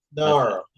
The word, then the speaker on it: Naru: Noru. Is that how you say her name? – Oh, her character in her character, the Naru. – Yeah, N-A-R-U.